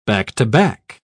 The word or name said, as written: back-to-back